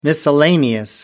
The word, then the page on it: miscellaneous: American English